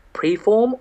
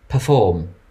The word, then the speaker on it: perform: Did you say preform or perform? – Perform. – Okay, carry on. – Okay, I will. Thank you. – I just heard preform and I just went, you've said it wrong.